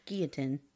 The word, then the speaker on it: Guillotin: So, the funeral for Dr. Joseph Ignace Guillotin. – Ignace Guillotin? – No, it's Guillotin. There's no E at the end.